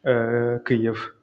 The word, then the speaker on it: Kyiv: It's like in Ukrainian, it is pronounced like Kyiv.